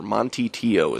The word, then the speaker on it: Manti Te'o: I think it's Manti Te'o, or Monty Te'o is how you pronounce it.